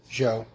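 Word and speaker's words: jeux: Joe. – Joe. Okay. Yeah. – Sorry. – It's, it's not gonna be. It's not. It's not a hard J. Joe.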